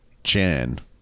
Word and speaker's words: Can: And I think it's actually pronounced Chan or something like Chan. – That. Yes, even though it's spelled C-A-N.